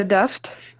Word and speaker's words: Duft: Okay, my name's Katya Duft.